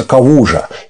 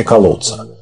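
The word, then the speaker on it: Kałuża: Калуци, вообще, он родился на территории Польши, которая тогда, современной Польши, которая тогда принадлежала Германии, и по польскому он произносится «кавужа», и означает это что-то типа «болото». Но все говорят по-немецки «колодца».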